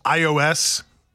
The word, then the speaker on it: iOS: I'm assuming it's pronounced iOS. – Yeah, like the operating. It's I for iPhone, iOS operating system.